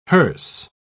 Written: hearse

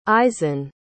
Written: isen

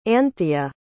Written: anthea

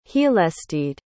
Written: heallstede

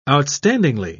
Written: outstandingly

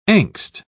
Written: angst

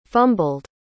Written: fumbled

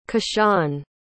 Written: Kishan

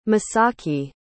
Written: misaki

